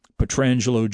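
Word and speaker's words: Pietrangelo: And Alex has got his Petrangelo jersey on. And Thank you for pronouncing it correctly.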